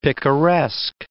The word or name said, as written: picaresque